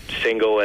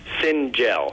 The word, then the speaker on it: Singel: so, uh, but yeah, Singel um, pronounced the normal way um,